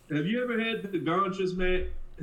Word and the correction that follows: concha: Am I saying it right? – Oh, concha.